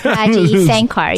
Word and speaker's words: Araji Sankar: I'm afraid to say your name. It's because of my stupid American dumbness. Araji Sanakar. – Araji Sankar. – Sankar. – You're so close.